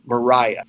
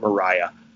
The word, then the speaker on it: Mariah: And his wife, Martha, and his mother, Mariah. She had an H at the end, so some people pronounced it Maria. I pronounce it Mariah.